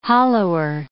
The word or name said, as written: hollower